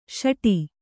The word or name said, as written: ಶಾಟಿ